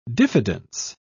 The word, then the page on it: diffidence: British English